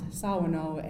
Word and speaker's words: Shawano: And then we have the city of Shawano, which we pronounce Sawano, and that means from the south or in the southerly direction.